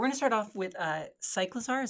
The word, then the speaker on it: Cyclizar: The official Nintendo pronunciation is going to be Cyclizar. So Cyclizar.